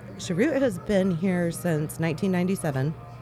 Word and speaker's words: Cheroot: And so when did you pick up, tell me how it's pronounced exactly so I don't chop it up. – Cheroot. – Cheroot. Okay. I didn't know, some people have been cheroot, and stuff like that, so.